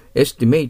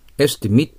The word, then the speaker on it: estimate: 106番は、 estimate って発音するか、 estimate って発音するか、これはわかりませんね。 estimate と読めば動詞です。 estimate と読めば名詞で、見積もり書などのことになります。